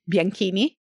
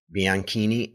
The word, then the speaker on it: Bianchini: I don't know how to pronounce her last name properly, Gina Bianchini. – Bianchini. – Bianchini.